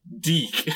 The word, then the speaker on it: DIC: I think it is pronounced Deek, because I was avoiding saying Dick Entertainment. – That's really how it's pronounced,